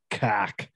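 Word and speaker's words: Koch: Dorothy Bush Coke, I guess she's married to the Coke. – I think that's cock. – Yeah, it's pronounced cock.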